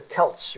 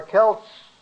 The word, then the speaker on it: Celts: In the British Isles, they were Celtic Welsh, the Celts, remember? Spelled also with a C, but pronounced like a K, C-E-L-T-S.